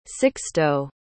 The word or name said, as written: sixto